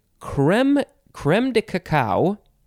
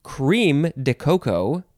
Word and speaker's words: crème de cacao: I've only heard of the first two, but you can say crème de cacao. It is spelled crème, second word D-E, third word C-A-C-A-O.